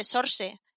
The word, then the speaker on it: Saoirse: Creo que se dice Sorse en inglés.